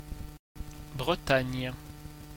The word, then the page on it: Bretagne: France; [bʁə.taɲ]